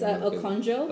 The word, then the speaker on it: Okonjo: Heir to, like, I don't know how to say it. – It's Okonjo. – Okonjo industry.